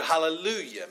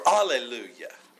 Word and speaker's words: hallelujah: Do you know what the word hallelujah means? Okay? Or alleluia. Okay, sometimes pronounced without the H. Alleluia, hallelujah. Okay, they're basically the same word.